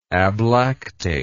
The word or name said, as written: ablactation